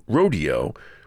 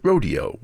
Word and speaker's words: Rodeo: As for how you pronounce the name of the ballet, Copland pronounced it Rodeo, while the choreographer Agnes de Mille pronounced it Rodeo. Madame de Mille was a very forceful presence who was hard to contradict, so it wasn't long before Copland himself started calling it Rodeo.